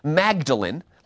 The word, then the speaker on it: Magdalen: Now, it's spelled Magdalene, like Mary Magdalene, but the British, who knows why, pronounce it Magdalen, like being tearful.